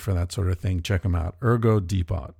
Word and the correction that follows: Depot: Or Depot. I'm not, how do you say that name? That word. It's a French word. Depot. It's like Colbert Report. Ergo Depot. We're also sponsored by Ting. T-I-N-G.